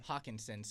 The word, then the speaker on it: Hockenson: Hockenson.